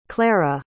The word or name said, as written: clara